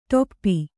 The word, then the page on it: ಟೇಪು: ṭēpu